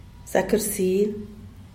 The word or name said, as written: zak rsil